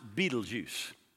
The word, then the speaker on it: Betelgeuse: One of them, uh, it doesn't look like it, but they actually pronounce it Beetlejuice. That makes it easy to pronounce.